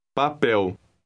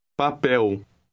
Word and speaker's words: papel: Papel. – Note the EL ending. The stress is on the last letter. Read word 4 again. – Papel.